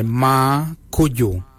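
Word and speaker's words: marrgoodjal: Ma geng is spelled M-A-R-R-G-E-N-Y. Ma geng. For the number 7 we say ma kuju. Ma kuju is spelled M-A-R-R, then it's G-O-O-D-J-A-L. For 8 we say ma marin. Ma marin is number 8. Ma is spelled M-A-R-R, then it's M-A-R-R-D-I-N-Y.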